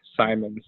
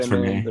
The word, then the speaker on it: Simons: Is that how you say his name? – Yeah, Anthony Simons. Yeah. Yep. Everyone wants to say Simmons or whatever.